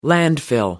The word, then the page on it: landfill: American English